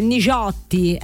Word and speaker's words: Nigiotti: Enrico Nigiotti, ecco, questa è la pronuncia livornese giusta, Nigiotti, ecco, con Tu sei per me.